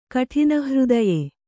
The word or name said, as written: ಕಠಿನಹೃದಯೆ